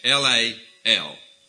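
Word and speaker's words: haw-lal (הלל): The word praise it's a Hebrew word, if I can say it right, halah. Or it's, the pronunciation is haw-lal. That's halah, okay?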